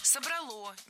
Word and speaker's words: собрало: В отличие от язычества, от того, что было до христианства, именно христианство собрало наш народ воедино. – Собрало, неверно. А правильно, как вы, наверное, уже догадались, собрало. Опять же, убираем ударение с окончания, однозначно. Только собрало и никак иначе.